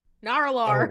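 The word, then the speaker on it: Nar'Alor: It is spelled Nar, apostrophe, Alor. – Nar-Alor.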